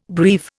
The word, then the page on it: breve: Indian